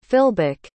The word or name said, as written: filbuk